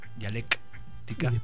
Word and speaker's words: Dialektica: Hay que recordar que dialéctica se escribe con K, dialéctica.